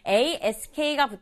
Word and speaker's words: ask: ask가 붙어서 ask. 마지막 sk는 이렇게 발음 소리만 나야지 ask 이렇게 세 박자로 발음하면 안 돼요.